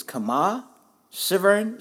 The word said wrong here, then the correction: Kemah Siverand: Sivaran, Sivaran. – Kemah Siverand.